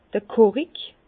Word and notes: d'r Korik: Bas-Rhin; Herrlisheim

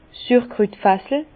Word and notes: 's Sürkrütfassel: Bas-Rhin; Herrlisheim

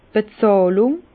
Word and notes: d' Bezàhlung: Alsatian; Bas-Rhin; Herrlisheim

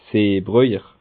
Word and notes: Seebröjer: Bas Rhin; Schiltigheim

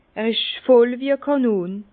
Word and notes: er isch voll wie e Kànon: Bas-Rhin; Herrlisheim

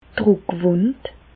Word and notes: d'r Drùckwùnd: Alsatian; Bas-Rhin; Reichshoffen

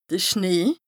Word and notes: de Schnee: Bas-Rhin; Strasbourg

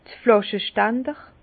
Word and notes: d'r Flàschestander: Bas Rhin; Herrlisheim

